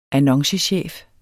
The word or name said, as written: annoncechef